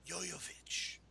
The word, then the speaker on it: Jovovich: So much so, I say her name right, and I kind of say it nasty. Jovovich. – Just deep breath on it.